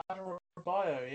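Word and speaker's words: Adarabioyo: Oh, Adarabio, yes.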